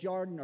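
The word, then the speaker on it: Yarden (ירדן): It's not actually pronounced this way. It's spelled Yardin, Yarden, but it's actually Yardane or something. It's very difficult to pronounce. But it's Yarden or Yardenet.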